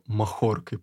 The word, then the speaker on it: махоркой: У нас бодяжили его махоркой. По-моему, даже правильно сейчас поставил ударение.